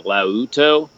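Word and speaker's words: laouto: I think his actual instrument is called the forgive my pronunciation, the lauto. It's L-A-O-U-T-O, but it's like bouzouki.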